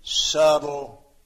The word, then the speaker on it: subtle: A very subtle man. When I was in school, a kid, I remember that word, and I read it, subtle. Subtle. And my teacher said, no, the B is silent.